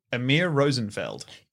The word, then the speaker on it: Amir Rosenfeld: Say his name again. – Amir Rosenfeld. – Okay, I got a little bit confused before. I thought you were saying like, a lowly Rosenfeld.